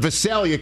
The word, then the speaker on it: Visalia: All right, Kyle in Visalia, California. I believe I got the pronunciation correct. Right, Kyle? – Yeah, that's correct.